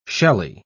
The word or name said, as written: shelly